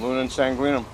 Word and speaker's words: Luna in Sanguinem: Yep, Luna in Sanguinem.